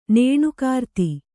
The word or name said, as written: ನೇಣುಕಾರ್ತಿ